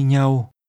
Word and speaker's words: nhau: Chúng tôi định đi với nhau. Nhau. Nhau. – In Vietnamese, together is literally with one another. The part that means one another is pronounced with a mid-level tone.